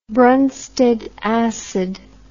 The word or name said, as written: broensted acid